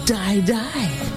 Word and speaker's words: Dai Dai: D-A-I-D-A-I. And it is pronounced die, die.